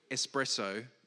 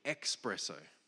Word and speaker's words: espresso: I used to think that the word espresso was actually expresso.